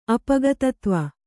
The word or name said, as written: ಅಪಗತತ್ವ